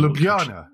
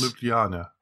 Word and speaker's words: Ljubljana: Ljubljana. – Yes. – Ljubljana.